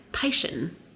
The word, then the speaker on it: Piscean: I said it right. Piscean.